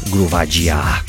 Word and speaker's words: GROOVAGIA: In the background, you're listening GROOVAGIA.